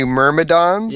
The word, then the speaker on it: Myrmidons: I think they recycled the art for the Elemental Myrmidians. – Oh yeah. – I think those are. I don't know if they were third or fourth edition art, but. – The Myrmidons? – Yeah, that's it.